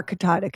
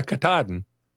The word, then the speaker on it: Katahdin: Katahdin. Can you do it? – Yeah, Katahdin.